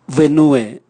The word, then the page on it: vwenue: Raga